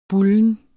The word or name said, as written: bulne